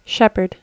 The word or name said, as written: shepherd